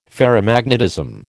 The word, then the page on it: ferromagnetism: American English